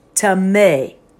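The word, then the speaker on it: tameh (טמא): If we take the word unclean, it's the Hebrew word tameh, T-A-M-E, and it's pronounced tameh.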